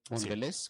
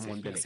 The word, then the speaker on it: Mondelez: ¿Lo pronuncié bien o no? – ¿Mondelez o Mondelez? Sí, sí, Mondelez o Mondelez. Cualquiera de las dos opciones está correcta.